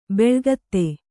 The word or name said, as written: ಬೆಳುಗತ್ತೆ